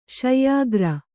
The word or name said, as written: ಶಯ್ಯಾಗಾರ